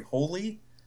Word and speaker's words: Hole: But it's in that language, it's actually pronounced Harry Holy. So he's like, holy? But it's spelled Harry Hole.